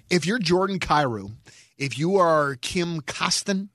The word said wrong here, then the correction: Klim Kostin: isn't that Kostin? – Klim Kostin. Klim Kostin, yeah. Don't get his name wrong.